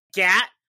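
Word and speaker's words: gyatt: Is it just like pointing at someone and being like, gat? Or is it like, she gat? Or I don't know. I've seen it on shirts, though. It's spelled G-Y-A-T-T. I think it's like, oh, she's gat that gat.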